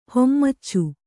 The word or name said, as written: ಹಾಮಚಿ